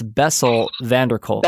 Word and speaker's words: Bessel van der Kolk: I can never is it Gessel Vander? I can't ever say his name. – Yeah, it's Bessel van der Kolk.